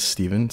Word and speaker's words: Stephen: My name is Stephen, spelled with a PH and eights. Supposed to be Stefan, but for some reason my dad thought it's pronounced Stephen.